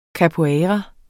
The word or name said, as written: capoeira